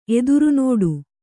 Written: ಎದುರ್ನೋಡು

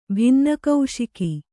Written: ಭಿನ್ನಕೌಶಿಕಿ

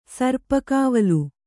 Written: ಸರ್ಪಕಾವಲು

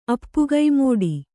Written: ಅಪ್ಪುಗೈಮೋಡಿ

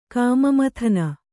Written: ಕಾಮಮಥನ

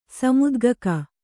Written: ಸಮುದ್ಗಕ

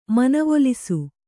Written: ಮನವೊಲಿಸು

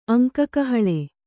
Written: ಅಂಕಕಹಳೆ

